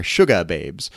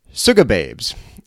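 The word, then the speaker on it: Sugababes: Not sugar with an R, Suga Babes. Suga Babes.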